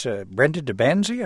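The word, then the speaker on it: Brenda de Banzie: It's Brenda DeBanzi. I hope I'm pronouncing that correctly. – That's right.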